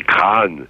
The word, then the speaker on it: Craon: 90 ans après, évoquer la chanson de Craon, qu'il faut prononcer Craon, c'est évoquer le chemin des dames, c'est évoquer les mutineries de 1917, mais c'est d'abord toucher le fond.